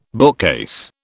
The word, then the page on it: bookcase: British English